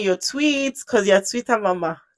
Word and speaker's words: TWTTR: The original name was Twitter. Like it's pronounced Twitter, but it was just TWTTR.